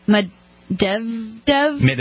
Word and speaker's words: Medvedev: Dmitry Medvedev. – Medvedev.